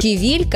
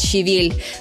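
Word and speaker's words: щавель: Кивиль как раз из таких. Правильное ударение на второй слог. При этом вариант «ща» в литературном языке – это все-таки ошибка, хоть и привычная.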